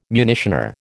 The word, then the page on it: munitioner: American English